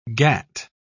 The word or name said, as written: gat